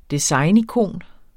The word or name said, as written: designikon